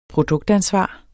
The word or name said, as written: produktansvar